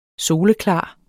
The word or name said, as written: soleklar